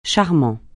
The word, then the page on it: charmant: francais